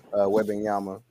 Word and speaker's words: Wembanyama: Like, granted, everybody, we talking about the upcoming draft class with Victor Wembanyama.